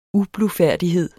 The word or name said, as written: ublufærdighed